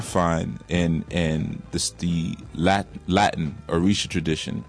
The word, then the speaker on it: Orisa: Like, you know, Orisha, a lot of times we'll see it as O-R-I-S-H-A. But it's actually O-R-I-S-A. The S is a sh. So even with Shango, it's really S-A-N-G-O, not S-H-A-N-G-O.